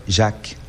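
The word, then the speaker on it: Jacques: E aí, a pronúncia lá em francês é Jaque. Também não tem esse S, não.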